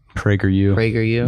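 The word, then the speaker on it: PragerU: Am I saying that right? – Prager you. Yeah, it's like Daily Wire kids.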